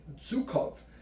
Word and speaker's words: Zhukov: So where is the first, one of the first reports that I came across was by a Russian marshal, and I'm sorry if I get the pronunciation wrong, Gregory Zhukov. – Well, that does sound. – Well, thank you. – Yeah. – All us non-Russian speakers agree that that's the correct pronunciation.